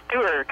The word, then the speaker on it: Duerr: Duerr. – Duerr. – Like some of us are doers.